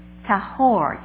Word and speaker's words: tahor: It comes from the Hebrew word tahor, T-A-H-O-R, accent on the last.